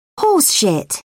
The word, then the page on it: horseshit: British English